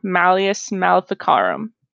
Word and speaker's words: Malleus Maleficarum: Has anyone heard of the term Malleus Maleficarum? – No.